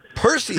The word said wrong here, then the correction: Perseids: Three syllables.